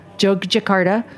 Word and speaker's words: Jakarta: Jakarta.